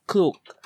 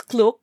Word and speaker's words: Klook: And then also you can get a discount through Klook. So I don't know, I pronounce it as Klook, but here in Hong Kong, people pronounce it as K-Look.